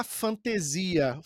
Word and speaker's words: Fantesia: E a nossa convidada, Tainá Fantasia. Falei certo o seu nome, Tainá? – Quase, quase. É fantasia, mas não tem acento, então eu aceito.